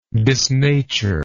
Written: disnature

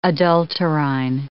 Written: adulterine

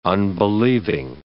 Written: unbelieving

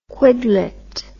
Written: quidlet

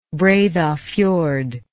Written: breidhafjord

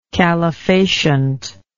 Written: calefacient

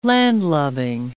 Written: landlubbing

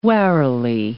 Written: warily

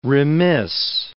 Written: remiss

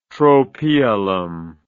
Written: tropaeolum

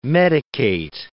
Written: medicate

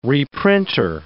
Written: reprinter

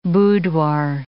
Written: boudoir

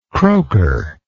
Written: croaker

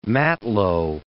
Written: matelot

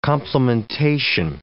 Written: complementation

